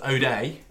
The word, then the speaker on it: Odoi: Pronounced O'Day. – O'Day, Hudson O'Day, Hudson Odoi. It's O-D-O-I is how it's spelt.